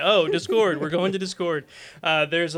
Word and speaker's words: Discord: It's Discord. – Discord. – The stress is on the first syllable.